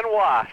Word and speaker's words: Wos: Is it Dan Woos? – Dan Wos.